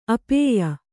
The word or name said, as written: ಅಪೇಯ